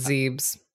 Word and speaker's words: Zebes: I always forget how to say this. Zebes? Is that how you say it in Metroid? – Zebes? – I believe it's Zebes.